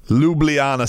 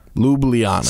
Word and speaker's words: Ljubljana: Ljubljana, Slovenia. – Ljubljana.